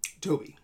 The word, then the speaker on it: Tobe: hopefully I say this right, Tobe Hooper? – Toby. – It is Toby. – I've been saying Toby.